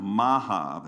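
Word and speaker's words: Maachah: Then Maha, that's how you pronounce it, his mother, King Asa, removed from being queen mother because she had made a detestable image for Asherah.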